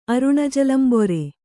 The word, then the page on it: ಅರುಣಜಲಂಬೊರೆ: kannada